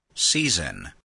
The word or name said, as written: season